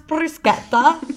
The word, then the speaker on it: bruschetta: So it's actually pronounced bruschetta. Io voglio mangiare la bruschetta, sì.